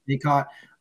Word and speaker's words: Bacot: Caleb Love, um, how do you say Bacot? Bacot.